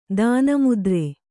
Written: ತನ್ಮಾತ್ರೆ

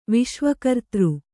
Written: ವಿಶ್ವಕರ್ತೃ

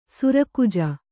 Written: ಸರ್‍ಖಾಜಿ

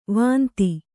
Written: ವಂದಿ

